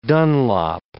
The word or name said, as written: Dunlop